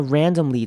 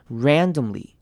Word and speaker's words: randomly: 好，看到 randomly 这个字，它的中间的 o r a n d o 的那个 o 呢，会念成轻音 randomly，因为前面的重音在前面 randomly， you just randomly wanted to， you just randomly wanted to。